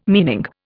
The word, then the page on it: meaning: Indian